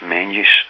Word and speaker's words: Menzies: Although it looks like Menzies, it should be pronounced Menzies.